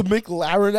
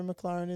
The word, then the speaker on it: McLaren: You were saying say Mick and then Laren. – You know, how do you say McDonald's? – McDonald's. – Yeah, imagine you going McDonald's. You're adding an extra thing. – McLaren. – Yeah. You're adding like a space in between.